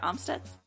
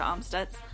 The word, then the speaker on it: Amstutz: And my name is Sandra Omstutz. on all social media platforms. You can find me at Sandra Amstutz. My last name is spelled A-M-S-T-U-T-Z.